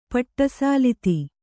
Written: ಪಟ್ಟಸಾಲಿತಿ

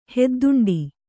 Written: ಹೆದ್ದುಂಡಿ

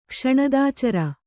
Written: ಕ್ಷಣದಾಚರ

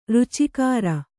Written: ರುಚಿಕಾರ

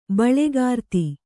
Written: ಬಳೆಗಾರ್ತಿ

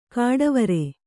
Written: ಘಟ್ಟವರೆ

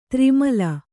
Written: ದುರ್ಮಿಲ

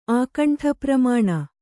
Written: ಆಕಂಠಪ್ರಮಾಣ

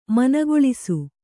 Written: ಮನಗೊಳಿಸು

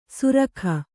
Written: ಸುರಖ

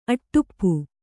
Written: ಅಟಾಪ

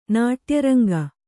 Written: ನಾಟ್ಯರಂಗ

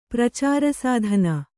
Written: ಪ್ರಚಾರಸಾಧನ

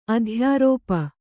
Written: ಆದೇಯರೂಪ

